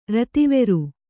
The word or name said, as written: ರತಿವೆರು